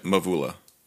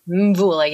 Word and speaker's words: Mvula: Mvula. – Mvula, yeah.